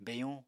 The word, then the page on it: beyuñ: Apatani